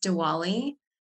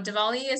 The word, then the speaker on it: Diwali: So I want to just start by saying it's not pronounced Diwali. A lot of people say the W, like the true, you know, way that we normally say it, but it's actually Diwali with a V.